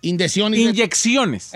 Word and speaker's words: inyecciones: Haya sobrevivido a 2 indiciones. – Inyecciones. – ¿Eh? – Inyecciones. – ¿Yo qué dije? – Indiciones.